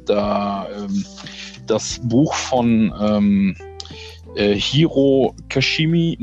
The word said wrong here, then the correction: Kishimi: ne, Kishimi und äh, Fumitake Konga.